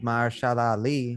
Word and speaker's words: Mahershala Ali: Then Marvel Studios, with their plans with Mahershala Ali, I hope I'm saying that right.